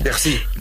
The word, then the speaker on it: Piercy: Piercy.